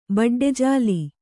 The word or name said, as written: ಬಡ್ಡೆಜಾಲಿ